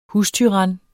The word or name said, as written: hustyran